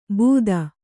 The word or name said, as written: ಭೂತ